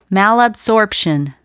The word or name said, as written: malabsorption